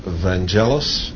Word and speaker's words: Vangelis: His name here in the United States is pronounced Vangelis, and I bet none of you can pronounce his last name.